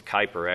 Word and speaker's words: Kuyper: I very much agree with Abraham Kuyper, who was, and I say it wrong, it's Kuyper actually, but he was a prime minister in Europe, also a very committed Christian.